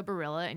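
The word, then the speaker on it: Baryla: But if you want to check it out, I'm at Jessica Barilla and you spell that Jessica.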